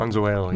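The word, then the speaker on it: Mazuelo: It's a big blend of Tempranillo, Graciano, and Mazzuello. Is that how you pronounce that? – Mazzuello, yeah.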